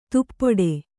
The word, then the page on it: ದುಪಟಿ: dupaṭi